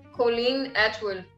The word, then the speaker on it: Colleen Atwood: ele trabalha muito com a Colleen Atwood, certo?